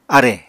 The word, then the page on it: arè: Apatani